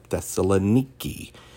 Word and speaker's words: Thessaloniki: And so, as my pastor, who was a missionary, would call it Thessaloniki, said that's how they pronounce it today.